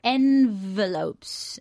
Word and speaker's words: envelopes: Muy bien, ahí acentuamos la primera sílaba. – Okay. – Envelopes. Además lleva una V, una V vibrada, ¿no?